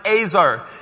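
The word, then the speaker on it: Azar (עזר): E-Z-E-R is the way it's spelled, but it's pronounced Azar.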